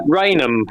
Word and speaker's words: Raynham: Is it Ranham or Raynham Hall? I think it's Raynham, isn't it, in Essex? – Raynham is how I've been told it's pronounced.